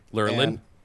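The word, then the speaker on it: Lurelin: So like on that, there's a little town called, just make sure I say it right, Lurin Village. – Yeah, Lurlin. Yeah, Lurlin.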